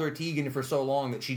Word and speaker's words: Teigen: Apparently, for years, we've been saying it wrong. She claims yesterday, and her mother confirms, it's actually... Teigen.